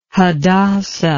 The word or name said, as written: hadassah